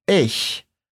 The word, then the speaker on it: ich: Quando você pronunciar esse som, vai perceber a folha tremendo, pois quando a gente fala esse ich, o ar tende a sair mais forte e para baixo.